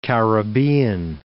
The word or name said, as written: Caribbean Sea